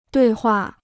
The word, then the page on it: 对话: duì huà